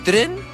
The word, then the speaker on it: Citroën: ¿Sabe usted que dice Citroën? Así se pronuncia. Pero solo lo conocemos acá como Citroën. Por lo tanto, por eso lo decimos así.